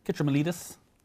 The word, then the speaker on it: Kitromilides: Kitromilides.